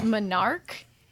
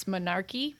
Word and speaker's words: menarche: I know how it's really said, but I prefer to say it incorrectly. It's Menarch. M-E-N-A-R-C-H-E. But I always like to pronounce the E at the end, so it's Menarchy.